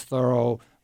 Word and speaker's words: Thoreau: His name is Thoreau, rhymes with furrow, as in the furrow in a garden.